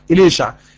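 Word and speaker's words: أليشع: طيب اليشع تعني الله خلاص، او اليشع عشع، النطق الصحيح اليشع، اليشع تعني الله خلاص.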